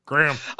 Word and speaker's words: Graham: How is that pronounced? How is it spelled? Is that Graham? Should it be Graham? Or is it actually G-R-A-M? – The A is silent. – Graham.